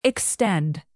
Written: extend

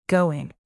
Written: going

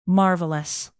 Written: marvelous

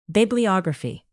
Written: bibliography

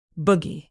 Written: buggy

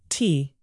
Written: tea

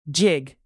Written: jig

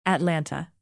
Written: atlanta

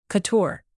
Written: couture

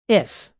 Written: if

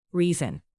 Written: reason

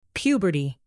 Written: puberty

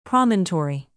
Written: promontory

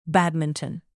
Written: badminton

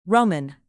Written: roman

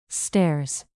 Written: stairs

